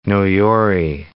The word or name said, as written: Noyori